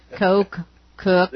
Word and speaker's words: Koch: How do you say his name? – Quit it. I knew you were going to. Koch, though. He said it earlier. – Well, I don't remember. – He's quiet now. – Cook. – Is this an inside joke? – Cook. – Yeah. – Cook. It's Cook. Blake Koch.